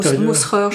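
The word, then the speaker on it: Musscher: c'est un tableau de Michel van Muscher, je ne sais pas si je prononce bien. – On doit dire Mousserer, je pense.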